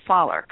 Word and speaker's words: Fowler: You do pronounce it Fowler, correct? – Yeah, very much so. I mean, obviously, there's different dialects within the U.S. as well, and but the way you're pronouncing it is the way that I would pronounce it.